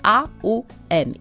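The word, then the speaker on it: om (ॐ): Mianowicie ona rozpoczyna się takim słowem om, pisze się om, natomiast fonetycznie to jest A, U, M.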